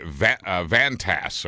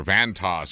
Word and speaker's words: Vantas: The nameplate will be Vantas or Vantas or Vantas. VANTAS.